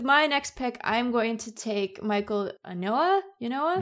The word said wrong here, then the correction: Ynoa: I, I, should you know how to say his name. it's Ynoa, who is a reliever.